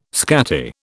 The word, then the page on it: scatty: British English